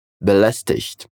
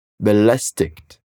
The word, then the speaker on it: belästigt: Lá eles pronunciam o som do IG como IK. Então você falaria belästigt.